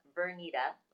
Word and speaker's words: Vernita: My name is pronounced Vernita, like you need me.